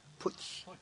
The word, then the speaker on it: Putsch: How do you say it? Putsch. Yes, he started a putsch at this time in Katak.